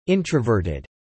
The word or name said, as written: introverted